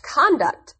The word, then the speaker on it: conduct: Actually, that word is pronounced conduct, and it differs in meaning from conduct.